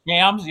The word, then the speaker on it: Shams Charania: The ESPN guy, Shams Charania. Did I say his name right? I want to make sure I don't. Is that? – Shams, yeah.